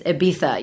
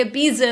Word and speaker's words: Ibiza: I'm like, I know, but we say Ibiza in the U.S. – Yes, I'm sorry. – I'm so sorry. I know that it should be Ibiza.